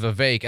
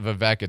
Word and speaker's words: Vivek: Speaking of Vivek, and that is how you say it, I learned. It's not Vivek, it's Vivek, so I'm going to try to adjust.